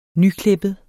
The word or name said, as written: nyklippet